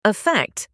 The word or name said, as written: affect